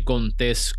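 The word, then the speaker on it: Contés: Mi nombre es Miguel Contes, con acento en la E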